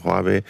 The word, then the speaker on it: Huawei: Huawei spricht man es Huawei, aus, ja. Man hört es so oft falsch.